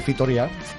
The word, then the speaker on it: Fitoria: Fitoria. – Sí. – ¿Lo he dicho bien? – Muy bien. – Fitoria. – Fitoria.